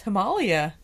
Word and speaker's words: Himalaya: I learned that it's pronounced Himalaya.